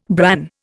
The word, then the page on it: bran: Indian